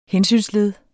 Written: hensynsled

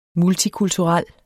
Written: MULTIKULTURELT

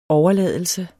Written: overladelse

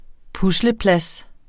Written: pusleplads

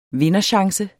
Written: vinderchance

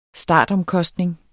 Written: startomkostning

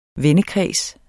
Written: vendekreds

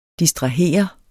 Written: distrahere

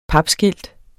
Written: papskilt

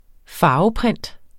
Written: farveprint

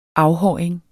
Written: afhåring

